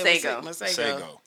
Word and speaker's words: Masego: I pronounce her name. Masigo. – Yeah, Masego.